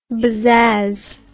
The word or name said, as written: bazazz